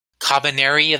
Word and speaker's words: Kabaneri: Be sure to listen to us next week, where we listen to where we watch and talk about Kabaneri of the Iron Fortress.